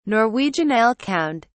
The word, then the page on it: norwegian elkhound: British English